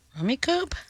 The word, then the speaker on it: Rummikub: Rummy Cub? Rummy Cube? – Rummikub? – No. Rummikub. More than 50 million units sold. – No. – Spell it one more time. – Oh, Rummikub. – Rummikub.